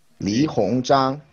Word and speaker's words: Li Hongzhang: Li Hongzhang.